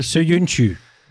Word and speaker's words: Söyüncü: Hij speelt bij Leicester Söyüncü. – Daar gaan we. Ik wil voor- en achternaam horen. Dit is de naam. En ik heb... – Çağlar Söyüncü.